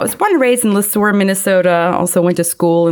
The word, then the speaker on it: Le Sueur: Jeremy was born and raised in Le Sueur. – You actually said it right. Pierre Le Sueur.